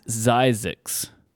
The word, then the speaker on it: Zzyzx: This is Zizix.